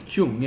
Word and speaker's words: Keung: Is it Kyung? – Kyung. – It's Kyung, yeah. – K-E-U-N-G.